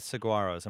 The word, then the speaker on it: Saguaros: And our final of the six teams in the AFL, the surprise Saguaros. Am I even saying that right? – Yes. – Saguaros. Right. It's Saguaros.